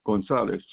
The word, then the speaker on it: Gonzales: Richard Gonzalez, G-O-N-Z-A-L-E-S.